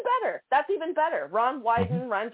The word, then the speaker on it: Wyden: Senator Ron Wyden, if I'm saying that correct, I think it's hysterical because it rhymes with Biden.